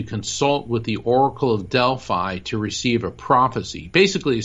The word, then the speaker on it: Delphi: In the ancient Greco-Roman world, the Oracle of Delphi, My Greek friends keep correcting me and telling me it's pronounced Delphi, but I say Delphi, was sacred to the god Apollo.